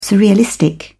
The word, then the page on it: surrealistic: British English